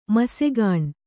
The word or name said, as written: ಮಸೆಗಾಣ್